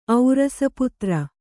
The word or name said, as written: ಔರಸಪುತ್ರ